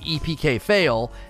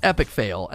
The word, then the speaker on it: EPK Fail: It wouldn't be the first time somebody didn't correct me on how to say their name, like EPK Fail. That name is supposed to be pronounced Epic Fail, and I called him EPK Fail for like a year, and then he finally corrected me at Guardian Con, and I was like, you frick, I've been saying EPK Fail for forever.